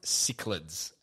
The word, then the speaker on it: cichlids: And it's pronounced cichlids.